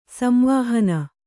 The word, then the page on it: ಸಂವಾಹನ: samvāhana